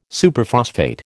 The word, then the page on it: Superphosphate: American English